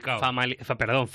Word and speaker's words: Famalicão: En el Famalicão, ¿se pronuncia así? ¿Se agarra? – Famalicão. – Famalicão.